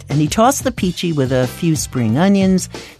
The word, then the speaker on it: Pici: The restaurant was Fresco Italian Cafe, and the dish was a Tuscan pasta called Peachy. That's spelled P-I-C-I.